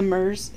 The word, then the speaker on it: Muirs: The Mers. It's spelled Muirs.